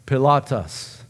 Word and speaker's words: Pilatus: Greek students, how should we pronounce it? Pontius Pilatus, right? That's technically his name.